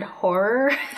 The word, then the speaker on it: horror: I have a really hard time saying the word horror. – Take on horrors. – Horror. Is it like a two-syllable word? – She's a horror. – Horror? – It's a two-syllable word, yeah.